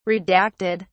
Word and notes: redacted: British English

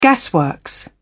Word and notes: gasworks: British English

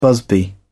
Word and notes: busby: British English

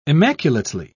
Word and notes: immaculately: British English

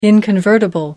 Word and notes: inconvertible: American English

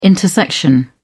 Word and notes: intersection: British English

